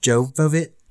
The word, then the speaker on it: Jovovich: So I don't know, but I like to watch Mila Jovovich. I can't even say her name right. I like to watch Mila kicking ass. So I think that... – What's her last name? – Jovovich.